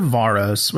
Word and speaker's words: Varos: So on the planet of Varos, which I pronounce it with the long A because that's how I am.